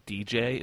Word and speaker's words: djay: Some of you may be familiar with the company or the software DJ. It's spelled DJAY.